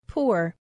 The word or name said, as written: poor